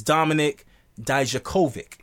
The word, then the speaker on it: Dijakovic: So one guy that came out to take his spot was Dominik Dijakovic. Did I say it right? – Yes.